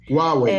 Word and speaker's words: Huawei: Huawei, não sei pronunciar direito. – Huawei.